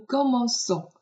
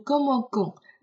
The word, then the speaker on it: commençons: 所以我们开始啊，我们会说 nous commençons。如果没有这个软音符号，你就会读成 nous commençons。